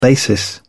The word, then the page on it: basis: British English